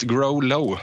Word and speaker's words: Grolo: Eh, jag har frågat honom själv och Grolo, växellågt. Grolo är det rätt uttal.